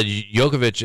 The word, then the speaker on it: Djokovic: Is it Djokovic or something? Is that how you say it? – Yeah, Djokovic is getting a little bit older and is not as dominant as what he was like probably 3 or 4 years ago.